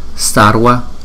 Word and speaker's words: sarva: Sarva, sarva, sarva, karma, karma, karma. Chúng ta đọc phải có chữ R trong đó ha.